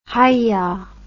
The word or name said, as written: hyah